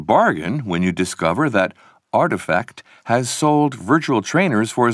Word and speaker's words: RTFKT: A few years later, in February 2021, another digital fashion company, RTFKT, pronounced Artifact, organized an online sale of 621 pairs of digital trainers it had designed in collaboration with an 18-year-old artist called Fowocious.